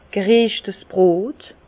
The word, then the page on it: gereschtes Brot: Alsatian; Bas-Rhin; Herrlisheim